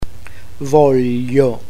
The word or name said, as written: voglio